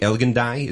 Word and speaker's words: Elgin Street: Elgin Street? Elgin Die? Is that how you say it? – That's the one.